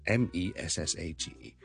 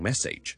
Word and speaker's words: message: 今日同大家讲一个好简单嘅字嘅发音，就系咧一个信息啊，message。好多人咧将佢读成 message，都难怪嘅，因为咧佢个串法咧的确咧 sage 呢个字，我系读 sage 嘅。